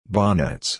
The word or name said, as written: bonnets